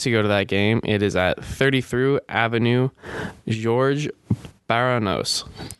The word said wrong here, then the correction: Georges Bernanos: Do not know if I said that 100% correctly. – 33 Avenue Georges Bernanos. – There we go. That is much better than my pronunciation.